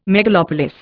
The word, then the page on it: megalopolis: Indian